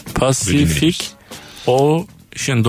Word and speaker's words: Pacific Ocean: Pacific Ocean kelimesindeki her C harfi farklı şekilde telaffuz edilir demiş. – Pacific Ocean, doğru.